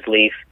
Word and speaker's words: Leif: My name is Leif, it's L-E-I-F, and so I spell it B-E-L-E-I-F.